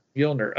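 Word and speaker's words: Mjölnir: Mjolnir, okay.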